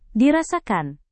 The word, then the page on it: dirasakan: indonesian